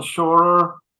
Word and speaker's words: Scheurer: And then my whole name, it's weird, but it's Christian Lorenz Scheurer.